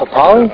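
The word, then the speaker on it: Papale: The real life Papali? Is that how you say it? Papali? – Yeah. – I think it's Vince Papali.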